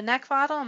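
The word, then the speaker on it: Neckvatal: That's Brenda Neckvottle. My last name is spelled N-E-C-K, like the thing you want to choke.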